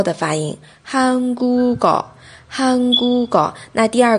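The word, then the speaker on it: 韩 国 高 (한국어): 韩国高，韩国高，这就是一个连音化，就是把下面的收音连过去，连到后边。发音的时候是这样子的哦，这是音变。那写的时候呢，还是要写成韩国哦这样子的形态。读的时候读成韩国狗，韩国狗。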